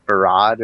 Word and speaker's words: farad: Is it the farad or the farad? – Yeah, actually, he has.